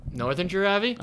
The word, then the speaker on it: Northanger Abbey: As I mentioned, while we're talking about book clubs, we're currently reading Nobody Knows by Mary Jane Clark and Northanger Abbey. Is that how you say it? – Northanger Abbey? – I think. Is it? – Yeah.